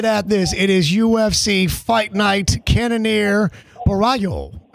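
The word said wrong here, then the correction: Borralho: How you say it? Barrio. Is that how the Brazilians say it? – Caio Bahio. – Bahio. Barrio. Well, all right. The R's, you know, they carry with the Brazilians.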